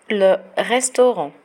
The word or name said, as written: le restaurant